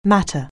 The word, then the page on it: matter: anglais